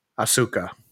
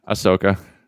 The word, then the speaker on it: Ahsoka: How many episodes is this Asuka. Am I saying that right? – Ahsoka. – Ahsoka.